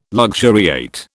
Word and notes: luxuriate: British English

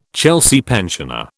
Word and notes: Chelsea pensioner: British English

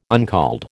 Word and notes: uncalled: American English

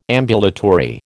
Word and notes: ambulatory: American English

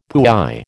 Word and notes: puy: American English

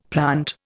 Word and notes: plant: Indian

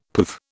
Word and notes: poove: British English

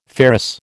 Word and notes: ferrous: American English

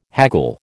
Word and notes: haggle: American English

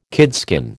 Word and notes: kidskin: American English

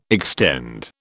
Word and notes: extend: British English

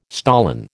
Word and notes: stolon: American English

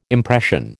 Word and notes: impression: American English

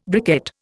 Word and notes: briquette: Indian

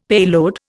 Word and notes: payload: Indian

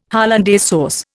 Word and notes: hollandaise sauce: Indian

